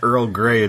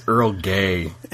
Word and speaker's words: Earl Grey: Except I know the last one is not Earl Grey, it's Earl Gay.